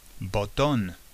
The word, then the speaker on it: botón: A big button on your bottom, because button is botón. A button on your bottom. Botón.